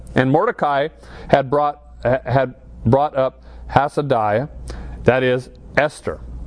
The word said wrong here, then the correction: Hadassah: And I said that wrong. How do you say that? – Hadassah.